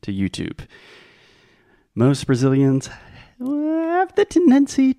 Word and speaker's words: YouTube: So the last sound is the B sound. There is no E at the end. In writing, there is an E, but in speaking, the E is silent. YouTube.